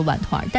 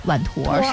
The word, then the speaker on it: 碗团: 那我们再来说说关于这个荞麦碗团，这个正常的读音叫做碗团，但是我们经常可能会读它叫碗坨，是吧。